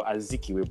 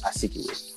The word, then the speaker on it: Azikiwe: of Azi with a Z or Z, replaces Z or Z with a S, so I would assume it's Azikiwe. Okay. But the name I'm used to is Azikiwe, so yeah. – Okay, well, I think that is how you say the name.